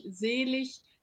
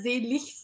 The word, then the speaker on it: selig: Wir hatten Sprecherziehung in der Uni und die Wörter, die auf IG enden, spricht man im Hochdeutschen tatsächlich mit ich aus. Also das heißt König, selig, Seligkeit. Also in Zukunft immer selig sagen, also mit CH.